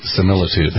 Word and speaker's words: Similitude: Is that how you pronounce that? – Similitude. – Similitude. Thank you.